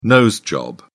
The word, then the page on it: nose job: British English